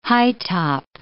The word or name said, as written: high-top